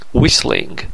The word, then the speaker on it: whistling: Whistling. تی تلفظ نمیشه.